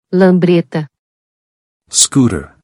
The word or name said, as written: Lambreta